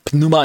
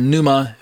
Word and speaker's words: pneuma: pneuma in the Greek, or pneuma, however, if you want to pronounce the P or not, uses this word spirit more often than any other letter or chapter in his writing.